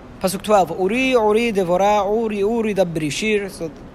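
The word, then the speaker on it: Uri, Uri (עורי עורי): Uri, Uri is Milera, meaning you read it with the emphasis on the end.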